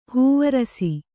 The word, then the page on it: ಹೂವರಸೆ: hūvarase